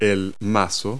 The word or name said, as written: mallet